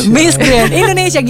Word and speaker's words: Miss Grand Indonesia: Miss Grand Indonesia, gitu cara bacanya.